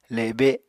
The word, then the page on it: le7be7: apatani